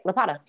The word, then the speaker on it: Lopata: Did I say that right? – That's right, Lopata.